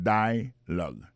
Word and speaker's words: dialogue: Alors, mot dialogue, ça c'est dialogue. Ça nous a remarqué là, nous a remarqué que l'accent tonique là tombé sous premier syllabe là.